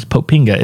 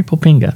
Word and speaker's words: Popinga: I thought it was Poppings, but it's Popinga. It's popping with an A instead of an S at the end. Avery Popinga.